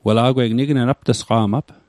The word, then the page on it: apt'sqa'q: aptîsqa'q